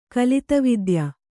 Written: ಕಲಿತವಿದ್ಯ

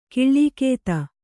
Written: ಕಾಲಗತಿ

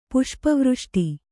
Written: ಪುಷ್ಪವೃಷ್ಟಿ

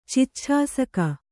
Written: ಚಿಚ್ಛಾಸಕ

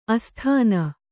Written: ಆಸ್ಥಾನ

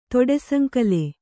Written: ತೊಡೆಸಂಕಲೆ

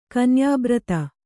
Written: ಕನ್ಯಾಬ್ರತ

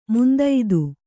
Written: ಮಂದತೆ

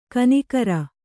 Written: ಖೂನಿಗಾರ

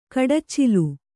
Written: ಕಡಚ್ಚಿಲು

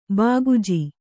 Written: ಬಾಗುಜಿ

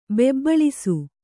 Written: ಬೆಬ್ಬಳಿಸು

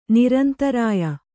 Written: ನೈರಂತರ್ಯ

